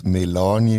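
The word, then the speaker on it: MELANI: Also wenn wir denen intern immer Melanie gesagt haben, natürlich ist es richtig ausgesprochen, ist es Melanie.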